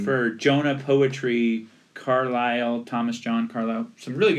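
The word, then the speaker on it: Carlisle: And you don't spell Carlyle with a Y in this case. You spell it with an I-S. – Yeah. – So a silent S in there. But Thomas John Carlyle, it is a great little poetry book.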